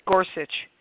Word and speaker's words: Gorsuch: So how are we supposed to run a government when you have Schumer and the leaders refuse to meet with Gor- Gorush? Am I saying his name right? Go Gorsuch.